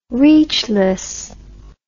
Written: reachless

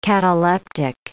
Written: cataleptic

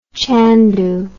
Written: chandu

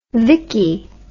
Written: Vicki

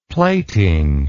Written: plating

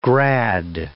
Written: grad

